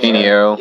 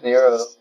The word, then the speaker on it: Pinheiro: We like, yeah, we brought some to Wyatt, and then we brought most of the others to John Pinheiro. – Is that how you say it? – We're getting that right. Right. – Piniero. – Yeah, Piniero. And then he also mastered the DP too, so.